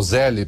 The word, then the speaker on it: Zelle: não vá para os Estados Unidos e fale para usar o Zelle, para não passar vergonha, que é Zelle, tá? Que se pronuncia.